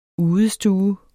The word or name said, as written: udestue